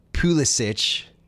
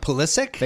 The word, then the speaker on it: Pulisic: So I'm saying that there's on FIFA, the commentators will say Pulisic. But apparently the way that you're supposed to pronounce it is Pulisic or Pulisic.